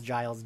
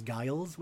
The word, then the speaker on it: Giles: Then we get them all meeting making a plan, and the Buffy bot calls Giles Giles, which I love. – Yeah. That was super cute. – Right. They didn't know to program a soft G.